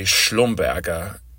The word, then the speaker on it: Schlumberger: Schlumberger, SLB, they may be known just as SLB now because Schlumberger is hard for people, or at least Americans, to pronounce. It's actually Schlumberger, if you want to be really technical about it.